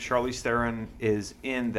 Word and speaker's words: Theron: Theron. Thank you for saying it correctly. Theron. Yes, I saw the interview where she said it's just Theron. It's not Theron for you French-speaking knuckleheads out there.